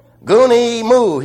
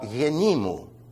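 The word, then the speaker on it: yenimu (γυναίκα μου): "Goonie moo," he says. Now, if you pronounce it the right way, Bob, it would come out yenimu. Isn't that beautiful? Not goonie moo.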